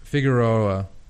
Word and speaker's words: Figueroa: And it's, I'm getting the name wrong. It's like the Figuera tunnel or Figueroa? – Something like that. – Is it Figueroa? – Figueroa. That's it. That's it.